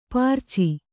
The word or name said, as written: ಪಿರಿಚು